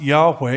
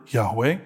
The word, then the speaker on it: Yahweh: We think it's pronounced Yahweh. It's actually not for certain because no good Jew would ever pronounce God's name.